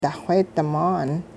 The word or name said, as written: daxweedi-maan